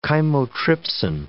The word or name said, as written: chymotrypsin